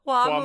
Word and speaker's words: Hua Mulan: Hua Mulan.